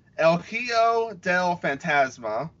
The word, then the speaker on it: El Hijo del Fantasma: Group B is Akira Tozawa, El Hijo del Fantasma.